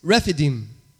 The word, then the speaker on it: Rephidim: And Moses takes them to this place called, let me see if I say this right. Rephidim, I believe that's how you say it.